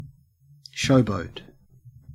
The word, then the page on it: showboat: Australian English